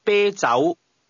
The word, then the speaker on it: 啤酒: This is pronounced with a high, level tone, followed by a mid-rising tone. Say again, beer. – 啤酒。